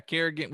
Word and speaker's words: Kerrigan: Is how do you say his last name? Kerrigan? – Yeah, Kerrigan,